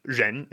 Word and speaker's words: ren: So Zhen is spelled R-E-N. And what he's asking is, okay, you start off with your tongue curled back, like all R pronunciations, as you'll see in the videos.